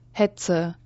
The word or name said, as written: Hetze